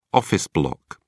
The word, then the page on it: office block: British English